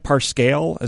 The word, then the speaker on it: Parscale: And is it Brad Parscale? Is that how it's pronounced? – Yes.